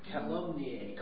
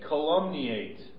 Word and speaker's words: calumniate: How do you say it? – Calumniate. – Calumniate or slander, and our title as follows.